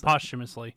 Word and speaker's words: posthumously: Is that how you pronounce that? Humus? – Posthumously. – Posthumously. Oh my God. Yeah, posthumously.